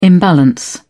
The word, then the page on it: imbalance: British English